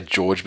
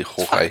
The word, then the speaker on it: Jorge: People say George, but I mean, come on, it's clearly Jorge.